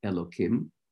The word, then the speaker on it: Elokim: Instead of the hey, we pronounce it with a k, a kuf, Elokim.